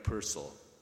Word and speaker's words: Purcell: he'd invited Frank Purcell. Like the composer, he invariably said on being introduced, in case someone might mistakenly put the accent on the second syllable.